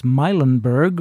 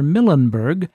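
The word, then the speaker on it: Milenberg: There's a discussion going on the 78 list about the pronunciation and whether it's Meilenberg or Millenberg, but I've always heard it as Meilenberg, so that's what I'm sticking with.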